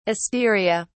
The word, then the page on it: asteria: British English